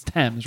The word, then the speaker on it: Thames: I watch Dr. Stephen Strange, Her Majesty's new court physician, as he takes a water taxi up the Thames, I believe it's pronounced Thames, or Thames, it's Thames, right? – The Thames River.